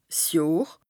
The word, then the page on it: 's Johr: Bas-Rhin; Strasbourg